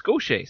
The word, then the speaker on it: Gaucher: But when I asked him, he said it's Gaucher. So I went with that. Because it's not spelled like, it's not G-A-U-T-H-I-E-R, Gaucher. It's G-A-U-C-H-E-R. But I guess it's pronounced the same way.